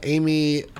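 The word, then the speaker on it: Aimee: Amy, once again, let's give contact one more time and then we'll scoot along, okay? – Sure.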